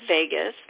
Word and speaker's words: Vagus: And vagus nerve is V-A-G-U-S. It sounds like Las Vegas, but it's pronounced Vegas, like Las Vegas, but it's spelled V-A-G-U-S.